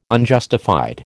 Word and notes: Unjustified: American English

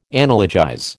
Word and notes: analogize: American English

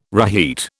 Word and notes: reheat: British English

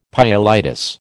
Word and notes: pyelitis: American English

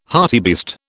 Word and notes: hartebeest: British English

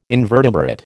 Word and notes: invertebrate: American English

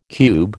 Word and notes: cube: American English

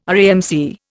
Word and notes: RAMC: Indian

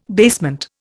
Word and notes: basement: Indian

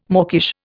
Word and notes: mawkish: Indian

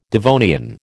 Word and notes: Devonian: American English

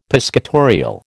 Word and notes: piscatorial: American English

